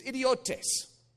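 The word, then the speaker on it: idiotes (ἰδιώτης): The word that I want to introduce you to is idiotes. That's actually how it's pronounced, too. Idiotes.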